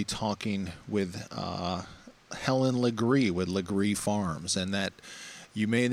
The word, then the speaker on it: Legare: It's a very, very old Charleston name, Legree, spelled L-E-G-A-R-E. And if you're new to the area, most people pronounce it Laguerre, but that would be a tip-off that you have moved here and are not haven't been here a long time.